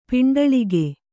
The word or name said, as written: ಪಿಂಡಳಿಗೆ